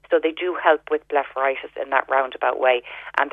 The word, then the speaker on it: blepharitis: So that'll benefit the blepharitis.